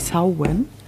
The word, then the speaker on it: Samhain: Samhain wird es geschrieben, aber ausgesprochen wird es, glaube ich, Samhain.